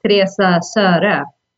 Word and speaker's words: Teresa Sara: So I'm very used to having it pronounced in the English way, but the Swedish way is, or Norwegian, that is Teresa Sara.